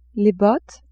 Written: bottes

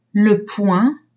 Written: point

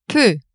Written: peu

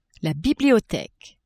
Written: bibliotheque

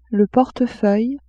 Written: portefeuille

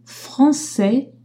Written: français

